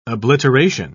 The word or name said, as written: obliteration